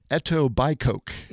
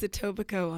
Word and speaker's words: Etobicoke: You are originally from a place that is pronounced not like it is spelled. – That's true. – It is spelled like Etobicoke. – Yes. – But it is not. – It is Etobicoke, Ontario.